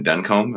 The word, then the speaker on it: Duncombe: And you quote, Stephen Duncombe. Am I saying his name right? – Mm-hmm.